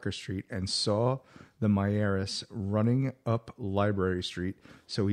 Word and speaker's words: Miyares: As we spoke to Myers, and it's spelled M-I-Y-A-R-E-S, Myers, he stated that he seen fireworks in the area of Parker while he was running, jogging.